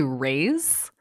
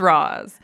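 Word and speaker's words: Ra's: I like I always pause before I say his name because so many people say Rays. – Rays. Rays Al Ghul or Raz Al I've always pronounced it Raz. – I've always heard Raz. All right, well, so for the for the remainder of for everyone podcast, else, just so you know, it's Roz.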